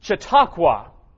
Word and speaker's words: Chautauqua: And Chautauqua is how you pronounce that, New York.